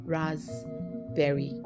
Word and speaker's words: raspberry: That's like R-A-S-P, then berry. The P is silent, so it's called raspberry.